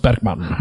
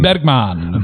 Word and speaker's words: Bergman: Bergman. Okay. So we like in Icelandic, we have all of these letters inside our words that they are there, but they're not really heard that very much.